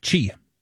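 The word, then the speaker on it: qi: Hey, how do you think you pronounce Q-I? – Chi. – And there you go.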